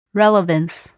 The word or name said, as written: relevance